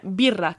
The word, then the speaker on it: birra: Birra. Can you roll your R's? – No, I can't. – It's double R. – Birra. – And it's birra. But yeah, birra.